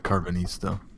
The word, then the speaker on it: Karvanista: Is it Karvanista? – I think they did say it more like Karvanista.